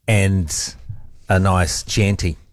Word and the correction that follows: Chianti: What's happened here is, I guess it's irony, but whilst I was addressing problems with my pronunciation of Michelin, I also added to it by mispronouncing Hannibal Lecter's words. Of course, it's a Chianti, isn't it? – Can we just confirm that?